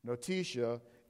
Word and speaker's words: notitia: If you're taking note, it's spelled N-O-T-I-T-I-A. N-O-T-I-T-I-A.